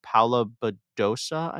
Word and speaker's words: Paula Badosa: Uh, the other player I would shout out is Paula Badosa, I believe is how you pronounce her last name. Yes, yes.